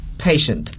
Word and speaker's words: patient: 在拉丁语当中呢 TI 往往要读作 sh， patient。